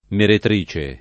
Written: meretrice

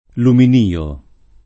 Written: luminio

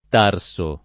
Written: Tarso

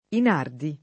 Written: Inardi

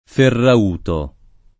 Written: Ferrauto

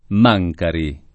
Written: Mancari

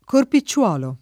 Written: corpicciolo

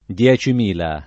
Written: diecimila

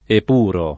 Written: epurare

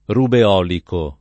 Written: rubeolico

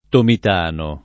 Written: tomitano

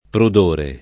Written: prudore

